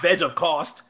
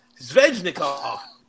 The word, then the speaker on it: Svechnikov: How do you say it? – Not Veg of Cost. Svechnikov.